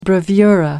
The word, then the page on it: bravura: American English